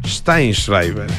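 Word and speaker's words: Sztajnszrajber: Pero lo dije mal porque es Stein Schreiber.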